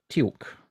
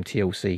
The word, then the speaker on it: Teal'c: It was a Teal'c. – No. – I was going to say, I've always pronounced his name TLC because it looks like that.